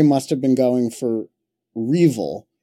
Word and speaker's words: REvil: It was R Evil, and it's spelled R-E-V-I-L. – And yeah, but some people pronounce it Revil.